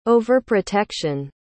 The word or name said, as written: overprotection